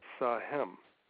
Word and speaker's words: SAHEM: Now, when you take the first letter of each one of those words, it's Sahem, S-A-H-E-M. Now, that's an innocuous two-syllable word, Sahem, and it's not unusual.